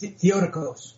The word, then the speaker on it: Theotokos: The one thing that I thought was kind of really interesting is you actually call these the Theotokos murders. – It's pronounced Theotokos. – Oh, Theotokos. I apologize. – No, it's not. No, just it's a hard word. It's a Greek word.